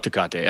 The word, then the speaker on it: Tecate: he's bringing a Tecate. Did I say that right? The Tecate beer? – Oh, the Mexican beer, Tecate? – Yeah, Tecate, yeah. I was like, of all fucking beers to be drinking in this fucking movie, you got that one out there.